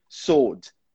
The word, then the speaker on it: sword: Mas, de forma alguma, pronuncia aquele W. É sempre sword.